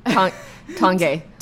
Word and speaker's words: Tange: Tange.